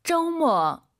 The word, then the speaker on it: 周末: 오유는 어우예요. 그리고 두 번째 글자는 모어 어가 들려야 됩니다. 잘 들어보세요. – 周末. – 한 번 더. – 周末. – 여러분 따라하셔야죠. 周末 아니고 저우.